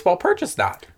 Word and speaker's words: Walpurgisnacht: Walpurgisnacht? Not snot. – It's not it? – Well... – I wanted to ask you how to say it, but I was too nervous. – It's Walpurgisnacht.